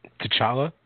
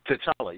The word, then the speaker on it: T'Challa: T'Challa? How do you say that? How do you say his name? – T'Challa? – T'Challa, yeah.